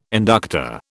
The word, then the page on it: inductor: British English